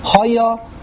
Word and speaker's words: hoya: Hoya, the word hoya, here's a grammatical point, is hoya milara. The emphasis is on the yud, on the last syllable.